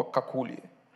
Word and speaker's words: какули: У нас в семье мы используем слово «какули». ударение на последний, как Это что-то итальянское такое, оно нас утешает.